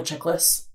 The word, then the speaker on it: Chiklis: Michael Chiklis.